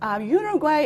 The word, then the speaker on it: Uruguay: actually, now just, you know, for your information, the correct pronunciation is either Uruguay or Uruguay. Uruguay is more European people pronounce it as a Uruguay in English, I would say. But in Spanish, you pronounce it as Uruguay.